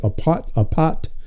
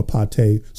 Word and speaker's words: apate: the word deceitfulness is the Greek word apat, which is spelled, or apate, depending on how you pronounce it, A-P-A-T-E, and it means delusion, deceivableness.